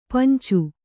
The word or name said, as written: ಪಂಚ